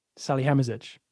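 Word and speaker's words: Salihamidžić: Salihamidzic.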